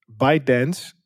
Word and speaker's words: ByteDance: ByteDance, en dat is de maker van TikTok, als ik het goed zeg. Zeg ik dat goed? – Ja. Zeker.